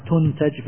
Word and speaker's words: تنتج: تنتج بالبناء للمجهول، هكذا تنطق به العرب،